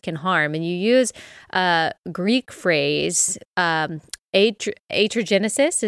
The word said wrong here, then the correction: iatrogenesis: Is that how you pronounce it? – Iatrogenesis. Yeah,